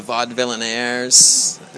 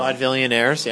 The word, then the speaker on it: Vaudevillainaires: Come hear the song October 30th with the Vaudevillainaires. – Yes. – I just totally pronounced that wrong. – Vaudevillainaires, yeah.